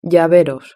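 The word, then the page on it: llaveros: Spanish (Spain)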